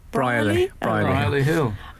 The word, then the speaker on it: Brierley Hill: Brierley Hill. – It's spelled like Richard Briers, yes. Brierley Hill.